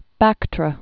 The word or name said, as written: Bactra